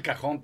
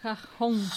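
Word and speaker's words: cajón: E il cajon. Però non so se sono brava come lui a pronunciarlo. Ci provo. – La J viene pronunciata H nel spagnolo catalano.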